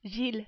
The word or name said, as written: Gilles